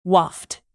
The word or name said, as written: waft